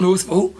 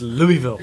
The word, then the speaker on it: Louisville: And I always get 100 emails saying it's not Louisville. – It's Louisville.